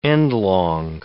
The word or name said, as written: endlong